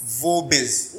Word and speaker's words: Vobis: I was working at Vobis Solutions. – What? – Vobis Solutions. – Vomit. – Vobis. – No, open your mouth, you know. – V-O-B-I-S.